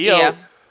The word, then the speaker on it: Io: Io, right? Io, right? Is that how you say it? Io. Yeah.